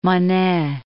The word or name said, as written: Monnet